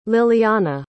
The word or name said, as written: lillyana